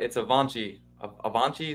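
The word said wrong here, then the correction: Achane: A chain.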